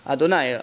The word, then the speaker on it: Adonai: So it's written one way, it's written Yud Kei Vav Kei, and it's pronounced Adonai, Adni.